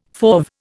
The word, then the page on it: fauve: Indian